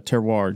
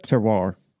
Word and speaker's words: terroir: This is their terroir gin. And how do you say that? – Terroir. – Terroir. – Yes. When you get into wine, it's a wine term.